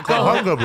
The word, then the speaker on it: Cahuenga: Cahuenga. Cahuenga? – Cowabunga, dude. – Cahuenga, bro. – Cahuenga. – How are H's pronounced? Did you forget your Spanish Cahuenga.